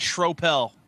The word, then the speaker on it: Schroeppel: And just before we get there, it's Schropel. If you want to try to say my last name, Derek Schropel.